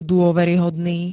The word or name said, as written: dôveryhodný